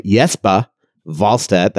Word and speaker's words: Wallstedt: We will call him Jesper Wallstedt. That's the way it's pronounced in Sweden.